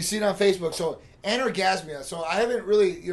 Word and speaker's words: anorgasmia: Anorgasmia, that's how you say it.